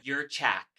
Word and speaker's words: Jurchak: Let's give a big, beautiful, and bothered welcome to the one and only Erin Dugan, Jurchak.